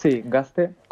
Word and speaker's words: gazte: Gazte, lo decimos en euskera, se pronuncia con S.